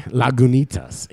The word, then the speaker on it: Lagunitas: Yeah, I learned how to say Lagunitas today. Lagunitas. You do it just like it's spelled, okay? – God, Dank Nug, guys.